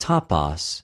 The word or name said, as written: τοπος